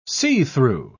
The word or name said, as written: see-through